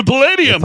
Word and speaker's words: Palladium: Is that, am I saying that right? – The Palladium. That is correct.